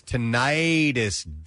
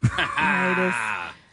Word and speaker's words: tinnitus: And I quote, it's tinnitus, dummy. – Tinnitus. – You can pronounce it either way. – But listen, hold on. – It's tinnitus or tinnitus. It doesn't matter.